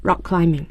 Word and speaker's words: rock climbing: 所以我们这个攀岩的话一定要注意它的使用方法，叫 rock climbing，前面的 k 发音非常轻，因为跟后面的 k 读在一起了， rock climbing。